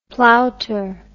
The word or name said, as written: plowter